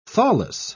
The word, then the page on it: thawless: British English